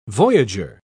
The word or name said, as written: voyager